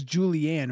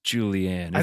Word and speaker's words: Julianne: So and it wasn't it was always Julianne, right? It wasn't Julian. – It's Julianne. It's spelled kind of oddly.